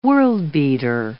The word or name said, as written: world-beater